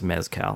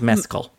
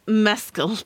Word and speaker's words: Mescal: I'm realizing that because it's Irish, his name is probably not pronounced Mezcal, which is what I was saying. I think you're saying it right. – Okay. I don't know that I'm saying it that differently, but yeah. – Mescal? Okay. Maybe it's Mescal.